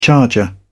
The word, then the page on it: charger: British English